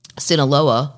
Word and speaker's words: Sinaloa: She's accused of acting as go-between to her famous drug king husband and his alleged, I always say this wrong, Sinaloa, I said it right this time, cartel cohorts while he hid from U.S.